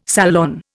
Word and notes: salon: Indian

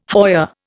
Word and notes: hoya: Indian

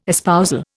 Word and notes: espousal: Indian

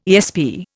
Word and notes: ESP: Indian

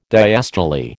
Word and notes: diastole: American English

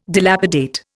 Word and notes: dilapidate: Indian

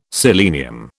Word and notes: selenium: British English

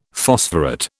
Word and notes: phosphorate: British English

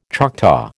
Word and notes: Choctaw: American English

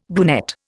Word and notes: brunette: Indian

